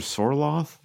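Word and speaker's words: Sørloth: I think it's technically pronounced Sorlot if you're Norwegian.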